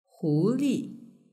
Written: huli

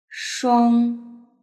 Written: shuang

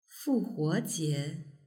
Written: fuhuojie

